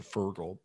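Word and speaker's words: Feargal: I think you probably pronounce it Fergal, but it's spelled Fear Gal.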